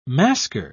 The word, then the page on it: masquer: British English